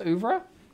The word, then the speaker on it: oeuvre: Is it pronounced oeuvre? – Yes, I'm with you.